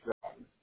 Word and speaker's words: Groton: The USS Nautilus is in Groton, how do you pronounce it? Groton, Connecticut, I think. That's how you pronounce Groton.